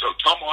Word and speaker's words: Ottumwa: Let's turn all the way back to your early days, and I'm going to try and say it correctly, Ottumwa, Iowa, right, which is in the southern part of the state of Iowa, correct? – Yep, it's Ottumwa, Iowa.